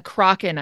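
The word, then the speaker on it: Crochan: So now we learn is it Croken or Crocken? I don't know. – Crocken. I listened again to the Crocken audiobook pronunciation. Yeah.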